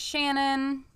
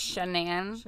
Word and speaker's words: Shanann: Also, I hear people saying her name is Shannon, Shannon-Ann, a lot of things. I heard her in her videos say her name is Shanann. – Shanann. – So that is how. – And he says Shanann. – And he says Shanann as well when he's referring to her.